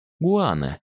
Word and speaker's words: гуана: Правда, сырье это носило не вполне благозвучное для русского уха название — гуана, с ударением на второй слог.